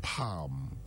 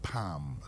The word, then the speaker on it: palm: Same with palm. Palm is not palm.